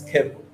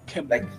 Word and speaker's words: Keble: Am I pronouncing that correctly? – It's Keble. Keble. Like pebbles.